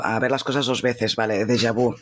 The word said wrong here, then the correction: déjà vu: déjà vu, ¿no? Déjà vu es, eh, se pronuncia déjà vu, de hecho.